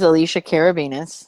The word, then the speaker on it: Alicia Karabinas: Well, you don't have to say my name like that. – My name is Alicia Karabinas. Normal people say it.